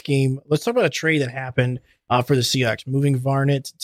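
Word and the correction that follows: Vannett: to, I believe that's how you say it. Vinette, my bad.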